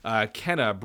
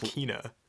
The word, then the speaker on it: Kena: Number 19, Kena, Bridge of Spirits. – It's actually pronounced Kena.